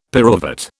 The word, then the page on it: pyruvate: British English